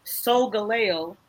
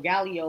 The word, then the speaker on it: Solgaleo: For Brooke, I went Solgaleo. Or Galio, or whatever. I don't know how you pronounce it. – No, so you had the first pronunciation correct. – Okay. Right. So. – S-O-L. – G-A-L-E-O.